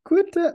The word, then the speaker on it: Quinta: Quentin Brunson What? Quinta, Quinta, Quinta.